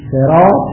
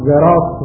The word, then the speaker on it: صراط: این صراط مثلا بعضی جا زراط رو تلفظ میکنن، بعضی صراط رو به سین تلفظ میکنن، صراط و صراط به سین و زراط اینا به یه معنا.